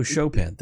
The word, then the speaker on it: Chopin: Oh, Chopin.